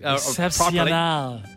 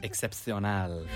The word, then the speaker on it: Excepcional: I'm trying to tell him how to pronounce it phonetically. – Excepcional. – Excepcional.